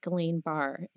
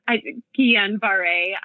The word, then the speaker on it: Guillain-Barré: And that led to you being in the hospital for like months with, I think it's pronounced Guillain-Barré. Is that right? – Guillain-Barré. I mean, everyone has a different pronunciation. – Yeah. – It all works.